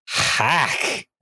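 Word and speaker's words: Haahk: Blood grimly tells him of hack. I'm choosing to pronounce that as hack. – It's H-A-A-H-K is the demon. – Yeah. I'll just say hack. – Just say hack.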